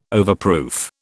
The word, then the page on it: overproof: British English